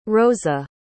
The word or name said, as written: roza